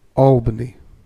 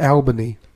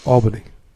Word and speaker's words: Albany: Albany. Not Albany. Albany. – Whatever. – If you're going to say the name of a capital of a fucking state, you're going to say it right.